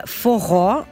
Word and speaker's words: forró: Então, Coco di Pedra Pichena, mitologia popolare, stiamo parlando, non abbiamo ancora iniziato a parlare del forró, che è, si pronuncia proprio così,